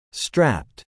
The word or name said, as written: strapped